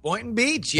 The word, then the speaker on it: Boynton Beach: Am I saying that right? – Boynton Beach. Yeah, Boynton now Beach.